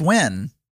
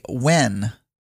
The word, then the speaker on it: Nguyen: Oh, it's Wen. – But I feel like I know him. Like if I go to Google Images and search for him. – You say Wen. That's how you pronounce the last name.